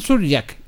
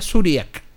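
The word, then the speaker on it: Šurjak: Y, todos le decían Surjak. Y Pato Bañado le decía Suriak. No era Surjak. – Porque la J se pronuncia como I.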